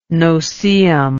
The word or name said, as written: noseeum